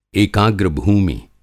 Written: एकाग्र भूमि

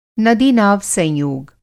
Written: नदीनाव-संयोग

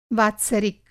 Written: वात्सरिक

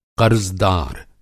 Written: क़र्ज़दार

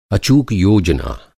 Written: अचूक

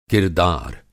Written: किरदार

